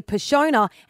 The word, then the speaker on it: Passiona: And he has put it out there and he's like, guys, we've been mispronouncing Passiona or Passiona wrong. It's actually Passiona. And then Cotties, who is the brand of Passiona, has confirmed that it is Passiona.